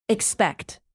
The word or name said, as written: expect